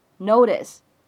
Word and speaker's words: notice: 它呢，在美式发音当中，明显当中字母 t 呢也是一个闪音，所以我们在美式发音当中读的时候呢，就感觉这个音啊，好像介乎于 t 跟的之间的感觉。 notice，那么前面呢再加上一个 short，这里呢又形成了一个不完全爆破，所以呢，整个台词当中的末尾两个单词，我们可以读成是 short notice，short notice，short notice。